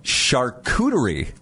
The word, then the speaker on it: charcuterie: Charcuterie. – Yes, I knew that's how you pronounce it. – Yeah, I don't even know how people pronounce it, but that's how you correctly pronounce it.